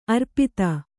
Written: ಅರೆಪಾದ

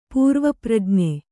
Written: ಪೂರ್ವಪ್ರಜ್ಞೆ

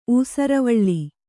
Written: ಊಸುರುವಳ್ಳಿ

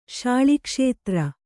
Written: ಶಾಳಿಕ್ಷೇತ್ರ

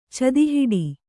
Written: ಚದಿಹಿಡಿ

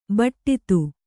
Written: ಬಟ್ಟಿತು

